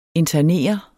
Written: internere

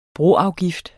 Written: broafgift

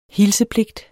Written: hilsepligt

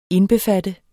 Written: indbefatte

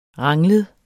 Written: ranglet

